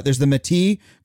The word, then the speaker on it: Métis: but there's the Métis group.